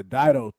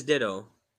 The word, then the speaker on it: Dido: Yeah, Dido, thank you. – Yo, that's how you pronounce it? I always thought it was Ditto.